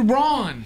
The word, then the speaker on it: Braun: Scooter Braun. – I say it that way because there's a basketball player with the exact same spelling, but he pronounces his Brown.